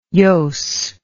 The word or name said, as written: jooss